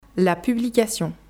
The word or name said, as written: La publication